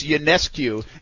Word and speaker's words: UNESCO: Hey, uh, real quick, uh, Brian uh, Jones text uh, tweets in and says uh, we're probably both fallen because it's UNESCO, not Ionescu or whatever the heck you said.